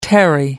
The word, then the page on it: tarry: American English